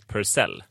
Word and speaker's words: Purcell: att hon har skrivit, ja men du vet väl, du vet väl att Eh, det uttalas Purcell.